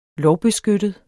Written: lovbeskyttet

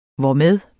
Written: hvormed

